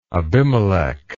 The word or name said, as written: Abimelech